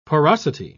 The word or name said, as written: porosity